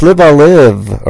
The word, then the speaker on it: Live A Live: It's Live a Live, or Live a Live.